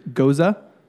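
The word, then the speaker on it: Gose: You remember that time we were in Denver and we found out the correct way to say G-O-S in a beer is Goza? – Yeah.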